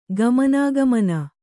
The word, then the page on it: ಗಮನಾಗಮನ: gamanāgamana